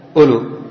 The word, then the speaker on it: أولو: الآن أولو هذه الواو ما ننطق بها هكذا، فلو حذفت ما تضر من حيث النطق أولو.